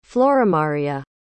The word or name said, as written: floramaria